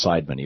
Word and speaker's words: Seidman: And it's Seidman. And I was pronouncing it Seidman, but somebody from Grand Rapids who knew him called and corrected me and said it's pronounced Seidman, even though it looks like Seidman.